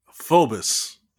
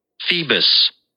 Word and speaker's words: Phoebus: Phoebus. – Oh, Phoebus. – Yeah, the O is not pronounced. It's like the O is silent.